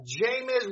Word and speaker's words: Jameis: Matt Ryan, Marcus Mariota, Jameis Winston. What a name, James is. That's how you spell it. – William, I believe it's pronounced Jameis.